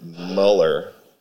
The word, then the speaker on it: Mueller: So I got a job at this guy's restaurant named John Mueller, or as he pronounced it, Muller. But John Mueller, just so we all know what we're talking about.